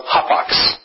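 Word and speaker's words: hapax (ἅπαξ): In the Greek, this is a word that is pronounced hapax.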